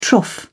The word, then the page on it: trough: British English